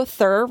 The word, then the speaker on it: Thur: Also, Thur rhymes with her.